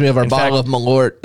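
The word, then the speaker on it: Malört: It's like Malort rhymes with wart.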